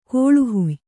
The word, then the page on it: ಕೋಳುಹುಯ್: kōḷu huy